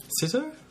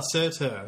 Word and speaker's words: Surtur: Sitter? – Sitter. – How do you spell it? – Sir. – Oh, Surtur. – S-U-R-T-U-R. – It's not S-U-R, but it's pronounced Sir the first part.